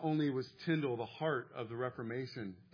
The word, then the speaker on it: Tyndale: But this is the life of William Tyndale. His name, his last name rhymes with spindle.